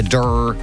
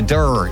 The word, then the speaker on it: Dirr: Have you heard of the Durr? The Durr? – No, spell it. – D-I-R-R. The Durr in Wexford, just outside Wexford Town.